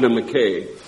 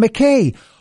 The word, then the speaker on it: McKay: His name should be Adam McKay, but it's pronounced Adam McKay. For so many years, remember, I've been here since 2009. For so many years, I refused to call the McKay Bridge the McKay. I called it the McKay because that's how we pronounced properly in Scotland.